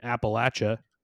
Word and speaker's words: Appalachia: It's about a loser who's growing up in Appalachia, and I pronounced that correctly.